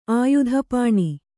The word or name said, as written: ಆಯುಧಪಾಣಿ